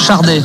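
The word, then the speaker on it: Sade: Par contre, ça se prononce Chardé, avec un R qui n'existe pas, mais en prenant, on dit Chardé normalement. Et je crois que d'ailleurs, elle le dit, oui, elle le dit elle-même, Chardé.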